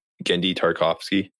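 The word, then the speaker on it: Genndy Tartakovsky: Gartofsky. – How do you say his name? – Gendy Tarkovsky.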